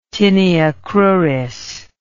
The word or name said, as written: tinea cruris